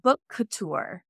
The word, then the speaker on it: Bookouture: And just for listeners to clarify, so Book Couture is spelled BookOuture, because I know that it can. – So it's like, Book Couture.